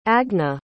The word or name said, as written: aghna